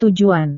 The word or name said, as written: tujuan